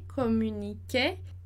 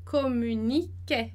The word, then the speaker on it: communiquaient: Então, a gente tem o AI ali no meio, e, communiquer. Então, como é plural, vai ter o ENT no final do verbo ali que a gente não vai pronunciar.